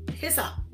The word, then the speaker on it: hyssop: the hy- hypus, hy- hip, I even ask how to pronounce this. Hyssop. Hyssop. That's what it is.